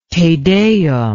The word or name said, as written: Te Deum